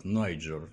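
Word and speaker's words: Niger: Кстати, этот самый Нигер, он только у нас называется Нигер. По-английски он произносится как Найджер, и река там Найджер, и страна называется Найджер, поэтому не так он звучит.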